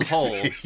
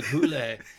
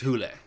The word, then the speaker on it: Hole: Now Hole, you know, it's spelled Hole. H-O-L-E. I think it's pronounced like Hule. In the like in its native tongue, it's Hule.